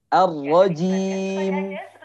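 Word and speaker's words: Ar-rajim (الرجيم): Tapi pas di akhir, dia berbelok. Jim. Jim. Nah, ini harus hati-hati ya. Dewi di Gorontalo. Nanti silakan di eh, apa namanya? diperbaiki lagi ya. Bacanya jim lurus. Ar-rajim. Nah, jadi bukan jim, jim seperti itu.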